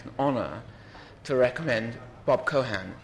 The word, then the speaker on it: Keohane: I actually only learnt just now that you don't pronounce the E. I've always said Bob Keohane or Cohane. And fortunately, in the emails, and he doesn't know that I didn't know you didn't pronounce the E, but it's Bob Cohan.